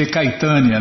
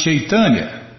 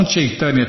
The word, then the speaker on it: Chaitanya: Ninguém conseguia pegar a pronúncia. Haviam ouvido falar de. Muita gente lê Caitânia, né? Haviam ouvido falar de Chaitanya, certamente, mas não desse novo livro.